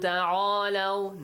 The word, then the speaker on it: تَعَالَوْا: قُلْ تَعَالَوْا.